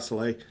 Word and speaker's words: Saleh: like uh, uh, the, Robert Salah, is that how you pronounce it? Salah Saleh. Um, you know, is there any buzz that this guy, uh, Jonathan DeCoster, is uh, an up and comer at all?